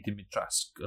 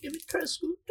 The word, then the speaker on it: Dimitrescu: Apparently, the E is silent, first of all, so it's Lady Dimitrescu or something like that.